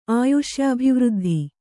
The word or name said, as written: ಆಯುಷ್ಯಾಭಿವೃದ್ಧಿ